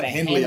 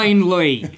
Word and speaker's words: Hindley: So, you know, I think the most mispronounced is Hindley. – Yeah. – Or Henley. Then Vegas. My guys in Vegas, sometimes they call it Henley. I love it. Henley Street. – Good. Hindley.